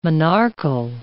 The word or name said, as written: monarchal